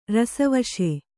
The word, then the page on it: ರಸವಶ: Kannada